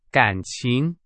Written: 感情

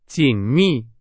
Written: 紧密

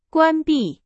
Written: 关闭

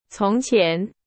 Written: 从前

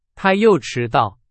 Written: 迟到

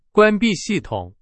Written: 关闭

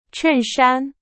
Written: 衬衫

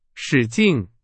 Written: 使劲

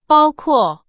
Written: 包括

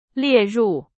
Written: 列入

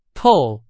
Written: 透

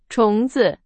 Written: 虫子

